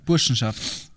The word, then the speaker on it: Burschenschaft: Burschenschaft.